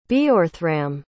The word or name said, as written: Beorhthramm